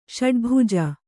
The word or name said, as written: ಷಡ್ಭುಜ